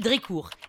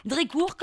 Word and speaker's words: Drécourt: Je m'appelle Marie-Cécile Drécourt. Drécourt, comme ça se prononce, avec un T à la fin.